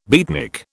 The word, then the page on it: beatnik: British English